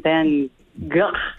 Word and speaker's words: Van Gogh: That was Vincent Van Gogh. – Yes, exactly. – Extra points for the accurate pronunciation.